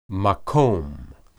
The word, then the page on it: maqom: Hebrew